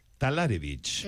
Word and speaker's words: Talarevich: Diana Talrewicz, ¿lo he dicho bien? – No. – No. Estaba convencido que me iba a equivocar. ¿Cómo se pronuncia su apellido? – Talarevich.